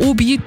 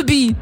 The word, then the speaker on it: Obi: Und das ist keine Abkürzung, aber Obi, das ist einfach nur Hobby auf Französisch ausgesprochen. – Oh. – Obi.